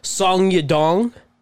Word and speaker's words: Song Yadong: And Song Yadong and Casey Kenny. – Yadong. – That is how you say it.